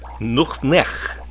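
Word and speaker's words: nuqneH: Nuch nech is how you say hello.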